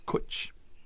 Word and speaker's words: cwtch: And it's kwitch. What do you think of that? But it's spelled CWTCH.